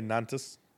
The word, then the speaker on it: Nantes: Yeah, I would say Nantes. – N-A-N-T-E-S, case... – Nantes? – Yeah, I don't know.